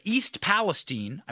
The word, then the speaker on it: East Palestine: I started saying like Palestine. But East Palestine, I believe that is the correct pronunciation.